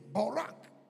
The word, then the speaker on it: barak: It's a word, barak, B-A-R-A-K, barak, but it's pronounced bow, barak.